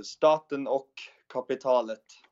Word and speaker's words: Staten och Kapitalet: How do you say that? – Staten och Kapitalet. – Oh, I fucking nailed it.